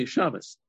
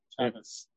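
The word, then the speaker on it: Shammes: So the Shamus, he takes the Sefer Torah, he gives the Rosh HaKnesset to we Ashkenazim in Lithuania frequently pronounce the Shin as a sin. So now you know. So they say Shamus, okay? – Shamus.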